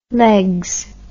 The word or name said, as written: leggs